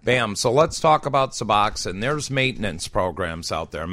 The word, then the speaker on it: Suboxone: Now, 6 years later, I have to take Suboxone.